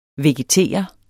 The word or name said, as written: VEGETERES